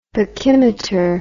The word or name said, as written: pachymeter